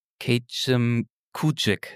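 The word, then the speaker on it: Kejimkujik: Kachimankujik National Park, so wird er ausgesprochen, ja? – Genau, ja.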